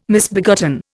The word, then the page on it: misbegotten: Indian